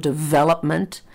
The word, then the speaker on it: development: And the example I used earlier is the word development. And notice the vel is longer than the rest of the word.